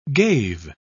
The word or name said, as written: gave